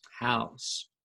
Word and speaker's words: Rosenhaus: Is it Haas or House? – House. Yeah. – Should be spelled like house from house.